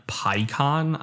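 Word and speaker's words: Peikon: So, this week it is Evan Picon, I believe is how you pronounce his name.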